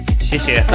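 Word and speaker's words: Xie xie (谢谢): Xie xie.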